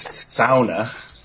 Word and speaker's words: sauna: We've built a bunch of little buildings on our property, a sauna, which for people that don't know any Finnish people is the correct pronunciation of sauna. It's sauna.